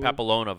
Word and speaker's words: papilloma: The human papilloma virus.